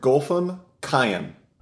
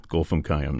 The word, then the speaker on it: Golfam Kayyem: Now, the title piece, The Night Triptych, three different movements here, can you pronounce the composer's name for me? – Sure, Golfam Kayyem. – Okay, just like it looks, Golfam Kayyem.